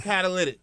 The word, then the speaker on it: catalytic: I want to say it's catalytic. It's not Cadillac Converter. Stop saying that.